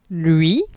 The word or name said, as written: lui